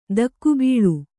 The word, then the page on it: ಧಕ್ಕೆಬಲಿ: dhakke ali